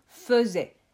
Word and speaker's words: faisais: Car tu dis hier je faisais mes devoirs, mais attention, ça s'écrit F-A-I, donc ça fait le son fait. Et on ne dit pas je faisais mes devoirs, mais bien faisais.